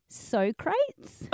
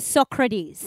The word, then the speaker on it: Socrates: So the correct way to pronounce it is Socrates.